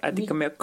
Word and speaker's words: Atikamekw: Ben, Nikotoaso, c'est dans ma langue Atikamekw. Moi, je suis Atikamekw et... Même là, je vais le prononcer comme il se doit, Adikomek.